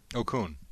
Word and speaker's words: Okung: This is Panthers offensive lineman Russell Okung. – Okung.